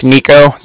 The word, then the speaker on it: Niko: And like I said, my name's Nico. That's spelled N-I-K-O. – Mm-hmm. – And calling from the Bank of Cardiff, and that's spelled C-A-R-D-I.